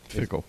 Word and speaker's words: Fickell: Is it Fickell or Fickle? – Fickle. – Is it Fickle? Okay. Rhymes with nickel.